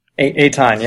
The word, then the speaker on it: Eitan: Eitan, yeah.